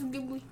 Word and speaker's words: Ghibli: I think it's Ghibli. – Ghibli.